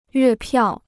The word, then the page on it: 月票: yuè piào